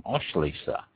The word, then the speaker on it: Auslese: and this is referred to as an Auslese. That's spelled A-U-S-L-E-S-E, an Auslese.